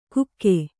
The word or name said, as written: ಕುಕ್ಕೆ